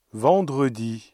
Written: vendredi

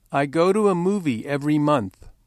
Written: every month

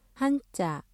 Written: 한자

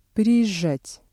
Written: приезжать

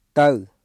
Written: ទៅ

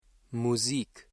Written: Musik

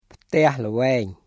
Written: ផ្ទះល្វែង

